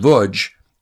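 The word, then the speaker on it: Łódź: and it was called The Chronicle of the Lodz, L-O-D-Z, pronounced Vudz, ghetto.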